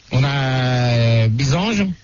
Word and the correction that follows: Bizance: Oui, c'est Bizance. – Ah, Bizance, pardon, excuse-moi de d'écorcher ton nom.